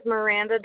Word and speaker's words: Miranda: My name is Miranda Dyer, MIRANDA.